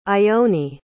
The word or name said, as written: ione